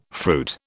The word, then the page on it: fruit: British English